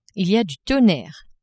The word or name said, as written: il y a du tonnerre